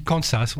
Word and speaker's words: Kansas: En France, d'où on dit Kansas, on ne prononce pas le S comme étant un Z.